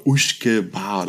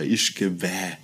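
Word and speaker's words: uisge beatha: eigentlich aus dem gälischen äh, Uschke Bar oder Ischke Bäh. Je nachdem, äh, wie viel Schotten man fragt, wie es ausgesprochen wird, äh, kriegt man auf jeden Fall eine Antwort mehr.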